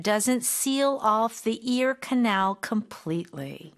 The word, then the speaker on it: canal: 캐널이 나왔네요. 캐널. 커넬입니다. 여러분들. canal 이라고 읽어주셔야 되고요.